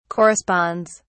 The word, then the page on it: corresponds: British English